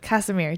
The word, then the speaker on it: Kassimir: Kassimir. Did I say it right? – Yeah. – Yes. – Kassimir.